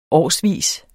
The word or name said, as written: årsvis